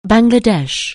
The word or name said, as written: Bangladesh